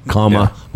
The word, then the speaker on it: Kamala: It wasn't Kamala. Until, it wasn't until those little kids came out and showed us all how to pronounce her name. Kamala.